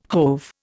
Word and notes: hove: Indian

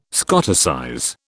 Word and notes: Scoticize: British English

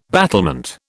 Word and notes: battlement: British English

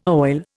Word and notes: oil: Indian

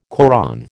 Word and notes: Koran: American English

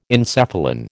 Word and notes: encephalin: American English

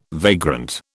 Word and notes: vagrant: British English

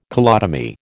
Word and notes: colotomy: American English

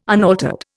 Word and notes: unaltered: Indian

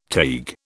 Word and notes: Taig: British English

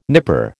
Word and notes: nipper: American English

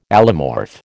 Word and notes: allomorph: American English

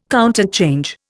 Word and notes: counterchange: Indian

